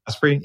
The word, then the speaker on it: Asprey: You know, today I'm speaking with Dave Asprey. Did I pronounce that right, by the way? – Asprey, yeah. – Asprey, okay.